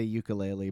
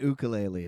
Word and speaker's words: ukulele: You know, in English you say ukulele, but he said ukulele. And I think like, ukulele is probably the right way to say it. – Well, no, I went online. It's both are okay.